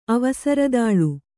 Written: ಅವಸರದಾಳು

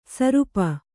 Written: ಸುರೂಪೆ

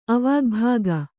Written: ಅವಾಗ್ಭಾಗ

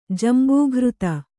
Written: ಜಂಬೂಘೃತ

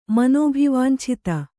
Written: ಮನೋಭಿವಾಂಛಿತ

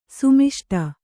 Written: ಸಮಷ್ಟಿ

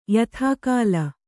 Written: ಯಥಾಕಾಲ

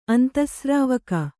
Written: ಅಂತಃಸ್ರಾವಕ

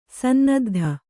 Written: ಸನ್ನದ್ದು

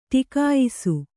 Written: ಟಿಕಾಯಿಸು

